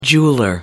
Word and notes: jeweller: American English

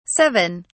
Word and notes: 7: British English

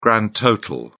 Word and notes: grand total: British English

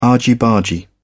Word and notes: argy bargy: British English

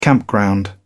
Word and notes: campground: British English

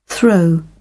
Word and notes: throw: British English

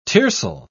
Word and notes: tiercel: British English

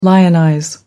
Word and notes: lionize: American English

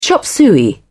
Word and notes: chop suey: British English

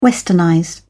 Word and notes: westernize: British English

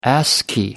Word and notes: ascii: American English

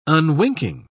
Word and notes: unwinking: British English

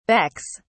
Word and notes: becks: British English

